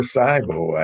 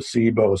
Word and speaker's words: SIBO: Her clinical expertise is in the treatment of irritable bowel syndrome, or IBS, and small intestinal bacterial overgrowth, or SIBO, or SIBO. I understand it's pronounced both ways.